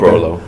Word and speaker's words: Frollo: Is that my pronouncing Frollo. – It? Frollo? – Okay. Frollo, yes.